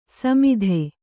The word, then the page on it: ಸಮತಿ: samati